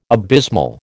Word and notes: abysmal: American English

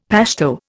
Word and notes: Pashto: Indian

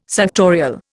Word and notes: suctorial: Indian

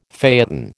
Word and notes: phaeton: American English

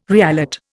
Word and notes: reallot: Indian

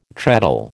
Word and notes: treadle: American English

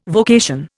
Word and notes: vocation: Indian

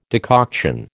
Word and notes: decoction: American English

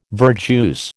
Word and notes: verjuice: American English

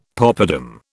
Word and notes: popadam: British English

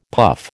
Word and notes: puff: American English

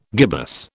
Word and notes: gibbous: British English

